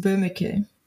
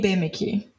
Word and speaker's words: Böhmicke: But the way Germans pronounce it is Böhmicke. I say Böhmicke, which is like the Brazilian version of that.